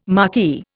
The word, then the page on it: marquis: Indian